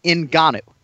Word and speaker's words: Ngannou: Okay, it's three syllables, right? Ngannou. I think for purposes of this show, that's one that you've got to figure out a way to get right. There's no you.